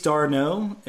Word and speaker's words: d'Arnaud: Uh, Chase d'Arnaud is is uh, D H A R, uh, and then the stress is on the N O H.